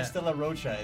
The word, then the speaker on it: Isla de Roca: I don't know if I pronounced that right at all. – To be honest, you probably pronounce it better than we do. – You're like Isla de Roca? Yeah. – Isla de Roca is exactly what we say.